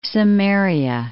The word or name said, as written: Samaria